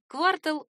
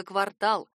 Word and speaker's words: квартал: Вариант «квартал» очень распространен, но ошибочен. Орфоэпические словари отмечают его как недопустимый. Правильно только «квартал», «квартальный».